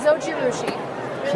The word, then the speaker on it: Zojirushi: Zojirushi. – Say again? – Zojirushi. – Okay. – And available. – I'm glad you asked. – In the marketplace. Thank you very much. Who knew? – That's great. Zojirushi is actually, and the chat's saying this too, a very popular brand.